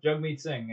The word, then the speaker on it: Jagmeet Singh: As a quick clarification, his name is actually pronounced Jugmeet Singh, and he actually had like a...